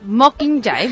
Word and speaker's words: Mockingjay: The Hunger Games, Mockingjay. No sé com es pronuncia això. – Mockingjay? Bueno, jo crec que ho has dit prou bé.